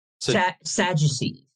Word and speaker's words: Sadducees: Sadducees. – Sadducees. Okay, so that's how you pronounce it.